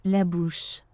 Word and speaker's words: La Bouche: La bouche.